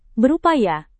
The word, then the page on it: berupaya: indonesian